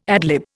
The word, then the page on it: ad lib: Indian